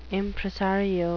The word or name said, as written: impresario